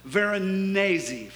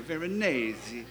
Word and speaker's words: Veronese: But do you know about 75 years after that was painted, another artist by the name of Paolo, I always say his last name wrong, I have to look at it to always say it right, Veronese, any Italians here, Veronese.